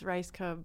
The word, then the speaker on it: Rajgub: It's Rajgub, but I like the way that you said it.